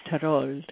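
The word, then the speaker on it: Thorold: Yes, St. Catherine's Tarold, Ontario, yes.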